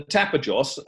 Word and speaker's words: Tapajós: Okay, and can you tell us a little bit more about the Tapajós? Is that how I pronounce it? Region in northern Brazil, where your obviously project's located. – Yeah. Well, as I said, the Tapajós,